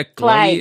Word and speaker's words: Clague: Um, this is the Sound Alchemy Podcast, and here we have Victoria Clague.